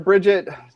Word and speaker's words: Bridget: So before we introduce our guest here, Bridget, tell us a little bit about you.